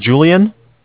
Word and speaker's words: Julian: Okay, my name is Julian. Mm-hmm. It's J-U-L-I-A-N.